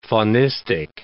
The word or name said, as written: faunistic